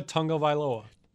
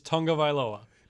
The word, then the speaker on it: Tagovailoa: It's Tua Tagovailoa. – Tagovailoa. Tagovailoa. I got to call my friend. – Even though there's no N in it, it's Tagovailoa.